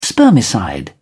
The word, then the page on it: spermicide: British English